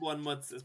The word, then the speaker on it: Bournemouth: It's pronounced Bournemouth.